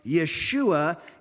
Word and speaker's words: Yeshua: The Greek word is a transliteration of the Hebrew word that we know in English as Joshua, but there's no J in Hebrew. It's pronounced Yeshua. And in English, the very same name that in Latin is translated Jesus is also translated Joshua.